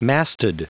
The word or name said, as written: masted